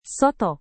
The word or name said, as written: soto